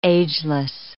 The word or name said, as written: ageless